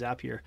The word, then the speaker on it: Zapier: But I would say something like Zapier um, is some people call it Zapier. I call it Zapier because they say Zapier makes you happier. Um, that's that's their slogan. So I think they intended it to be pronounced Zapier.